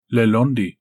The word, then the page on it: Lelondi: Galindri